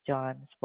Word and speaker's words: John: And my name is John Spoldas, J-O-H-N. – So that's J-O-A-H-N? – J-O-H-N.